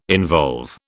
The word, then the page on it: involve: British English